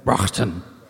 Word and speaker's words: Broughton: This is from Tim, I don't know how to pronounce this, B-R-O-U-G-H-T-O-N. Broughton. – Broughton. Broughton? – No, I said it right.